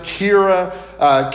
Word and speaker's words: Kyria: Now, there are some people that will say that the term that is used right there, I think it's pronounced Kyria, K-Y-R-I-A, a Greek term, which actually may reference a real live person that they were writing to.